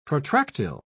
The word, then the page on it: protractile: British English